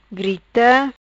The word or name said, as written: Grita